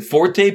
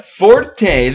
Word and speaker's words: forte: The reason why people call it forte is because it's spelled with an accent on the e, right? Yeah. French people don't pronounce the accent because they're all ridiculous.